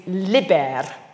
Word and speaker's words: liber: Its root is liber, with a short I, the Latin term for the skin, bark, or rind of plants.